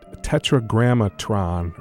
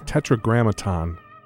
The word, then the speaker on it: Tetragrammaton: And I can never say this right. Tetragrammatron, or it's Tetragrammaton, not Tron, Records took that, and it's got the catalog number, I think, T-0001.